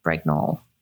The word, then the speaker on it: Brignull: I really struggle with this last name, but I think it's pronounced Brignull, B R I G N U L L.